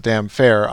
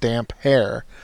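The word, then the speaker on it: Damphair: She pronounced it damn fair. I don't know how the audiobooks pronounce it, but in my mind, Aaron's nickname has always been pronounced damp hair.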